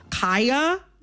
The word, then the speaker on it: Kaya: I am going to, in this conversation, pronounce Kaya, the rapper, with the long I.